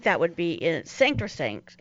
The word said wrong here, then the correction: sacrosanct: I said that wrong. – Sanctosanct? – Sacrosanct.